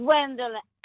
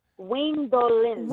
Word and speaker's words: Gwendolyn: It's Gwendolyn. No, it's without the G. A lot of people spell it with the G, but it's Gwendolyn. – Gwendolyn. – Ah. Like, look out the Gwendolyn. – Or it rhymes with Mistolin. – Exactly. Mistolin. Clean the floor with Mistolin. Gwendolyn.